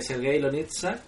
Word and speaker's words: Sergei Loznitsa: una criatura gentil, y es de eh, Sergei Lonitsa.